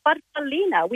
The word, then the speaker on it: farfallina: Well, growing up, as we're little girls, Mum called it a farfallina, which in Italian means a little butterfly. – Oh. – How do you say it? – Farfallina. – Farfallina. Farfallina.